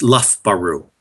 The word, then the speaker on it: Loughborough: In English, it's spelled Loughborough University, but in the UK, it's pronounced Loughborough.